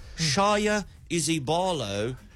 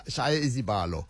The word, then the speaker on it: Shaya Izibalo: Shaya Izibalo Maths Institute. – you butchered the pronunciation of that. Can I have a go? – Please do. – Shaya Izibalo.